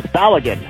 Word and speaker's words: Balogun: Tony, it is Balogun. Hello, Balogun. – Wait. Pronounce his first name for me. – I don't know. I just say Flo. – Okay. It's Balogun, not Bologan? – Hello, Balogun.